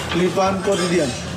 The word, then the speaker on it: Le Pain Quotidien: Le Pain Quotidien.